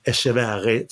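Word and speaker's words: Etcheverry: Och han möter en eh, en världs, eh, världsdelskompis, argentinaren 76-rankade Thomas Martin Echeverri. Echeverri, spanskt uttal på den.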